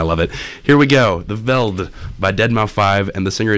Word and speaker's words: The Veldt: The, like T-H-E. Veldt. V-E-L-D-T. – I think it's just called The Veld. – The Veld. The Veldt. – Silent T.